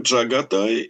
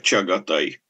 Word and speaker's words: Чагатай: Надо сказать, что имя этого второго сына произносится по-разному. Иногда произносится как Джагатай, иногда как Чагатай.